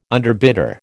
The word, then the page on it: underbidder: American English